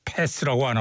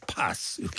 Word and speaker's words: Pass: 28위 앞에 들으신 음악은 29위 뮤지컬 유스의 Pass the Touch 영국 친구들답게 패스라고 안하고 파스 이렇게 발음하는데